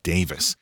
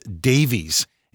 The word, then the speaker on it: Davies: Now, I've heard that their last name should actually be pronounced Davis. But here in America, for literally decades, it's been pronounced Davies.